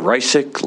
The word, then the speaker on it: Reisig: My name is Doug Reisig, last name is spelled R-E-I-S-I-G.